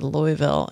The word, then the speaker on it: Louisville: This comes from Louisville. Oh, did I say it right? – You did. – Louisville.